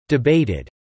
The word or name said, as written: debated